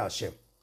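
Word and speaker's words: השם: Y deberá meditar el santo nombre Hei Shin Mem, que se pronuncia Hashem.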